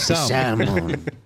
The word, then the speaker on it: salmon: It's salmon. – With all due respect, you're wrong. – It's salmon beach. – I'm not wrong. – The L is silent, dude.